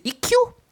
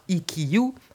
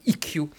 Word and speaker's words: Ikkyu: et puis, au bout d'un moment, ils m'ont dit, mais c'est Ikiyu Ikiyu donc maintenant, je sais que ça n'est pas IQ en détachant les, les syllabes, mais IQ.